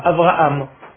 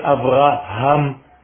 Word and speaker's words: Abraham: Et donc, c'est de faire attention, surtout quand on vient de France, ou d'ailleurs, de prononcer la lettre He, qui est difficile pour les francophones, qui prononcent généralement le He comme un Aleph, Abraham. Ce n'est pas Abraham, c'est Abraham.